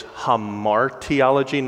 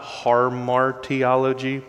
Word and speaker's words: hamartiology: I added an R in an already hard to pronounce word. It's hamartiology, not harmartiology, but hamartia is the Greek word for sin, and hamartiology is the doctrine of sin.